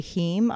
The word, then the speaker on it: heme: And that's spelled H-E-M-E. – H-E-M-E. That's right. – Heme.